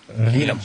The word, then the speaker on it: Keenum: And John Elway's still a dumbass for not pronouncing Cave Keesum's name correctly. That's been me the whole time, man. – Case Keesum. Keenum.